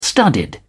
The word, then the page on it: studded: British English